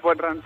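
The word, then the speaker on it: bodhrán: Hem anat buscant aquí instruments irlandesos i ens sortia una cosa que es deia bodhran, però potser ho pronuncio molt, molt malament. Vaja. – No, és bodhran.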